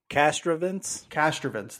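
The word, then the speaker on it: Castrovince: Piccolo came up with in an article from Anthony, I'm going to call it, how do we say that in the last name? – Kastrovince, that's what we're going to say.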